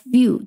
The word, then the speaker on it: view: A room with a view，其实也是我特别喜欢的一本英文的经典小说。再比如说，我们对于某一个问题想问别人的观点，你就可以问 What's your point of view？Point of view 就是观点的意思。 view 当然也可以做动词。问 view 原本的意思就是 see 看的意思，它的读音很简单，一个加上一个 u，这个 u 和字母 u 发音是一样，u view。